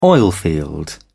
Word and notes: oilfield: British English

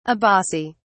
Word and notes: abaci: British English

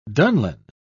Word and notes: dunlin: British English

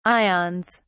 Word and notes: ions: British English

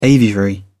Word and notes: aviary: British English

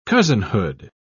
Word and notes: cousinhood: British English

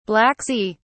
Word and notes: Black Sea: British English

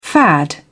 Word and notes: fad: British English

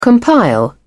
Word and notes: compile: British English

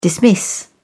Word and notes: dismiss: British English

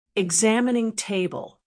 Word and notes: examining table: American English

